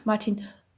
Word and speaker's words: Martin: Und auf der dänischen Seite arbeitet Martin Martin Rode, das ist dänisch ausgesprochen, ungefähr so. Martin Das ist auch ein Running Gag in der Serie, weil deswegen müsstet ihr auch in O-Ton schauen.